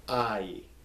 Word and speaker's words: Ai: The same thing with, there's a city in Israel in the Old Testament, Ai, and it's Ai. You pronounce them both, A-I, but you do it fast so it comes out to I, Ai, all right, not Ai or anything, it's just Ai, the city of Ai.